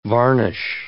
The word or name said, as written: varnish